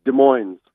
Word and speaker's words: Des Moines: How do you pronounce it? – Des Moines.